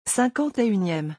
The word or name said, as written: 51eme